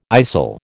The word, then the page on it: istle: American English